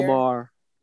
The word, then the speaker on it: Maher: Bill, is it Meyer or Mayer? – Bill Maher.